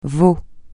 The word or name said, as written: votre